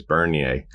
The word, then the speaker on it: Bernier: So, and I just realized I was pronouncing his name wrong the entire time. It's Bernier.